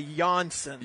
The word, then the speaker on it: Johnson: So if you have a little Swedish in you, you don't say the J, right? – Yeah. – Is that right? – Yeah. – So it's a silent J. It's a Johnson.